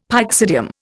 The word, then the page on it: pyxidium: Indian